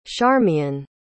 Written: charmian

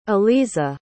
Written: aleeza

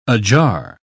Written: ajar

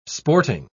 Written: sporting

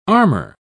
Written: armor

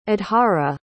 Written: adhara

